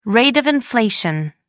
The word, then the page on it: rate of inflation: American English